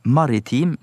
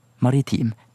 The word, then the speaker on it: maritim: Men i moderne, normert bokmålsuttale, og nynorsk også, så er de fleste nå samde om at både massiv og massiv, til dømmes i NRK-en, må være heilt korrekt, altså valfritt. På samme måte som maritim nå også er fullt gangbart som korrekt uttale ved sida av maritim.